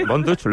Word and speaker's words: Von Dutch: Así que, Von Dutch, ¿lo he pronunciado bien? – Sí, muy bien.